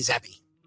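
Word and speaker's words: Zappe: This Bailey Zappi, is that how you pronounce it? Zappi or is it Zappi? – That is how they pronounce it. It's Bailey Zappi.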